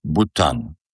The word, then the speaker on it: Bhutan: The name of the country is Bhutan. It's spelled with H, B-H-U-T-A-N.